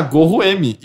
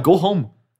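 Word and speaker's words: Goho-M: Porque o item pra você escapar de dungeons é tipo G-O-R-O-M. E eu nunca me toquei sendo Gorro M. E aí alguém falou, cara, não, é Go Home.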